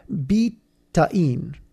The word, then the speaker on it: betaine: Betaine, because it's an organic compound that was originally found in beets, so that's where the beet comes from, and it doesn't look like betaine. It's BETAINE, so it's definitely not spelled the way it looks.